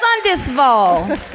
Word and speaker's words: Sundsvall: And so it's the therapist, the police, doctors, they all get into a van, and they drive up to Sundsvall. Sundsvall. I just think if it's Sundsvall, it should be Sundesvall.